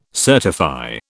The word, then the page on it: certify: British English